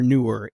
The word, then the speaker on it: Neewer: And I also wanted to throw in, I can never figure out how to pronounce these. Newer or newer? N-E-E-W-E-R. – Newer. – Newer. It's like Kermit the Frog. Newer. Kermit the Frog saying newer.